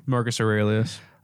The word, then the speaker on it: Marcus Aurelius: Marcus Aurelius.